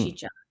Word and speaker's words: chicha: And they really experiment with a mix of, you know, different genres, everything from like surf guitar to cumbia to Peruvian chica. Mm. Chicha. How do you say C-H-I-C-H-A?